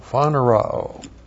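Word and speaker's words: phaneroo: P-H-A-N-E-R-O long O. Phanerōo.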